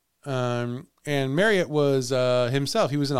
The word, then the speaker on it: Marryat: M-A-R-R-Y-A-T. – Marriott. – Marriott.